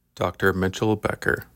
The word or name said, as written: Dr. Mitchell Becker